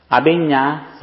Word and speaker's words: Abhinna: A-B-H-I-N-N-A. – Abhinya Sutta. – Is the N the same as in Spanish? Is it an enye? – Yes, enye.